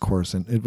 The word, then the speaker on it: Coruscant: I think it's pronounced Coruscant, which is the capital city of the Empire in Star Wars. – Yeah, totally. – That would be pretty awesome. – How do you write that? What's a it's Coruscant? C-O-R. – Like corset. – C-O-R-U-S-C-A-N-T. – Oh my God. – It's like Coruscant.